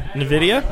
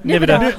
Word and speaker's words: Nvidia: Nvidia? – Oh, my God. He butchered that, too? – Nvidia. Yeah, I did. Nvidia.